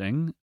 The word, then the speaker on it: Ng: By Celeste Ng. I think that's how you pronounce her last name.